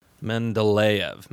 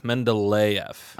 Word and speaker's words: Mendeleev: Mendeleev. Mendeleev. – Okay. – Mendeleev. Mendeleev.